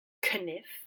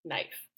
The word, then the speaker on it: knife: The spelling was kept and the pronunciation changed so that you say it the way that you say it now, which is knife.